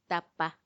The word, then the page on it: tappa: Macuiltianguis